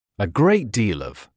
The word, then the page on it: a great deal of: ə greɪt diːl ɔv; э грэйт диːл ов